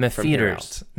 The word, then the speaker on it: M'Pheeters: Mefeeters. Mefeeters, and it's spelled M'Pheeters. – Yeah. – Which we think is pronounced Mefeeters, but it looks like Mefeeters.